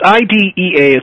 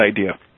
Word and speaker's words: IDEA: Is that pronounced IDEA or IDEA? – I assume it's IDEA or IDEA. Yeah. It's IDEA. It's all in capitals, but we're going to call it IDEA.